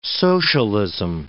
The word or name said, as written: socialism